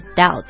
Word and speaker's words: doubt: 那虽然这个词的拼法是 doubt，但它的它读起来呢不是 doubt，这个 b 是不发音的，是 silent，所以就是 doubt。